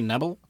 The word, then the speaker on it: Knebel: I think they actually pronounce his name that way. And I think that's Knebel, right? It's supposed to be Knebel. Knebel. – It's not Knee.